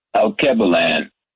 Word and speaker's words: Alkebulan: That's A-L-K-E-B-U-L-A-N, Albert Cleanland. Where did that name come from? I heard someone talking about Alkebulan.